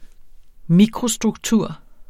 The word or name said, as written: mikrostruktur